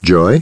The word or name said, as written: Joy